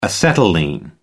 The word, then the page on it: acetylene: American English